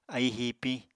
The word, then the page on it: aíi hiipiñ: apatani